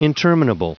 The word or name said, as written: interminable